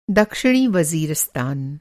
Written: वज़ीरिस्तान

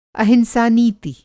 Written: अहिंसा नीति

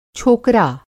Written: छोकरा

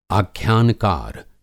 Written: आख्यानकार

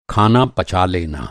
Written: पचाना

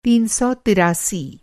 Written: तीन सौ तिरासी

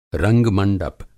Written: रंग-मंडप

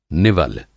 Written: निवल

